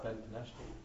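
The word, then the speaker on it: Benveniste: Rav Chaim Ben Avishti? What? – Ben Benashti.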